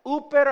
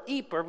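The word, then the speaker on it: iper (ὑπέρ): Uper or iper, depending on how you want to use old or young or new pronunciation of Greek. I prefer iper because it has the idea of hyper.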